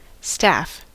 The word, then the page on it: staff: /ˈstæf/; [stæf]; /stɑːf/